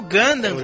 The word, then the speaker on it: Gundam: Suit Gundam. – Não, é que eu não sei se no Japão fala de, outra, de outro jeito, né? – É o Gundam, cara.